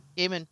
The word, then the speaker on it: Gaiman: Okay, so probably one of my favorite authors is I never know how to pronounce his name, but Neil Gaiman. – Gaiman. – Neil Gaiman.